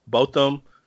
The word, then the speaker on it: Botham: And both them, I'm not sure the pronunciation, but I'm gonna say Botham. Botham Jean was the. – That is the correct pronunciation.